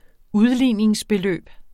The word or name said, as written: udligningsbeløb